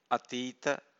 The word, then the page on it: atita: Pali